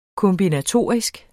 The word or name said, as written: kombinatorisk